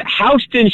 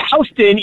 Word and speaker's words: Houston: The way we pronounce it is correct. The reason it's called Houston Street in New York is because the person they named the street after, his name was Houston.